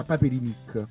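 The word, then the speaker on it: Paperinik: Allora aspetta, aspetta, io chiesi a Valentina De Poli, allora Paperinic si pronuncia Paperinic. Ok.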